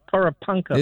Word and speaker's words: Porepunkah: I can never say that right. Porepunkah. – It sounds good.